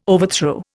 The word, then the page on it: overthrow: Indian